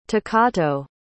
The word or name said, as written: takato